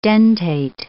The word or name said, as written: dentate